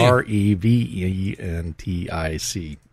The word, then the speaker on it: Creventic: according to a statement released Tuesday by organizer Creventic. Uh, I believe that's how that's pronounced. – Looks pretty good to me. C R E V E N T I C.